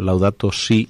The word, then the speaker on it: Laudato si: Bueno, Laudato si, mi señor, mm, así es como, seguro que lo he pronunciado mal, ¿verdad? – Perfecto.